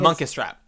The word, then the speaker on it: Munkustrap: I'm Muckraker, whatever the fuck Munkustrap.